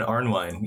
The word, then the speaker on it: Arnwine: Ash Ryan Arnwine. Yep, Arnwine.